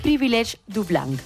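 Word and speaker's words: privilège du blanc: privilege du blanc.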